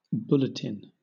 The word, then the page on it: bulletin: British English